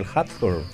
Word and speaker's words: Hawthorne: En esta fecha también falleció en 1864 el escritor estadounidense Nathaniel Hawthorne. – Y en el año 2004 falleció el poeta paraguayo Elvio Romero. – ¿Qué te pareció como pronuncié ahí el apellido, ¿no?, con mi británico nativo, Hawthorne? – Totalmente.